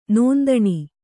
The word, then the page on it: ನೊಂದಣಿ: nondaṇi